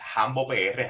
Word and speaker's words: Hambo: Eh, mi, username es HamboPR, Hambo se escribe con H al principio, eh, como Rambo, pero Hambo, HamboPR.